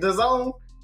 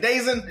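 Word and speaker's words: DAZN: Oh, and is that how you say it? The Zone? I've been calling it Dazen. – Dazen. Nobody knew how to pronounce it when it first came out. They finally called it the Zone.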